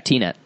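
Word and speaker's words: Tenet: Is that how it's said? – Yeah, T-Net.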